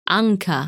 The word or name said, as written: Ankaa